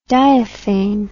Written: diaphane

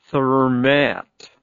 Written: thermette

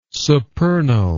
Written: supernal

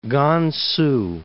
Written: Kansu

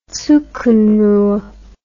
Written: zugunruhe

